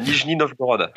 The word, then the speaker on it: Nijni Novgorod: Novogrod. – Presque. Alors, c'est plus proche que celui qui a dit Istra. Je suis à Nijni Novgorod actuellement.